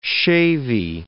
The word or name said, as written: shavie